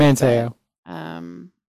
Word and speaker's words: Manteo: Manteo.